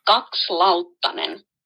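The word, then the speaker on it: Kakslauttanen: And I can tell you that the name is pronounced Gokslautanen.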